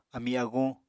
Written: ami-aguñ

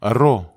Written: ero

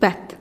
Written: bet

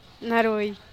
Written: narui

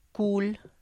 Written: kul

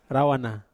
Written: rawana

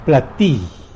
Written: plati

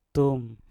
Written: tum

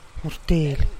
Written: gurtilih

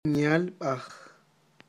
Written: myalb'aj

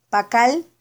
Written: pakal

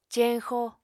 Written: ceñxo

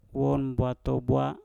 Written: wom bwatobwa

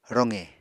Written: roqe

